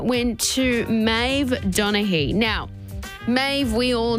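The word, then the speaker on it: Meadhbh: This particular Maeve, you would fall over your tongue if you tried to figure out how to pronounce her name the way it's spelled. So it's M-E-A-D-H-B-H.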